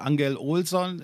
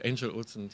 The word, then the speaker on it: Angel Olsen: Äh, wir kommen nochmal zu einem Song, den du mitgebracht hast, The Waiting von Angel Olsen, stimmt das so? Mhm. Nicht, nicht Angel Olson, äh, nee, sondern. Angel, Angel Olsen, die Dame kommt aus Chicago und spricht sich dann amerikanisch aus.